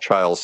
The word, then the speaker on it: Chiles: So there's a book called Zen Sleep by Eric, and I believe his last name is Chiles, pronounced Chiles, C-H-I-L-E-S.